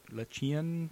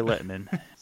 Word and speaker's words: Lehtinen: I was looking at it going, Lechien? – Nope. he's Kari Lehtinen.